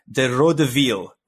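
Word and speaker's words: De Rød-Hvide: I've been practicing my Danish pronunciation, so I think it's pronounced De Røde Vil, which is translated to the red and whites, which I find that latter term much easier to say.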